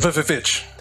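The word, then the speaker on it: VVitch: Vavitch.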